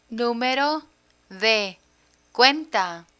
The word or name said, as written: numero de cuenta